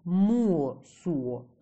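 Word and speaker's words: Мосо: А старинный народ Моссо, произносится Муосуо, также часто упоминается самоназвание На или Нари.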